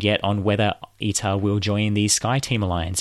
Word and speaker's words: ITA: Alitalia has now been replaced by a new airline called ITA or ITA. I'm not sure how you actually pronounce that. It's ITA, which stands for Italia Trasporto Aereo.